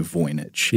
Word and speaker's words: Voynich: And it is a manuscript known as the Voynich manuscript, or the I, have also heard it pronounced Voynich, but I think we'll say Voynich.